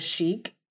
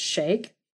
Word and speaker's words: sheikh: So this sheikh, and I looked up how to pronounce it because I thought it was sheikh, but it's not. It's sheikh.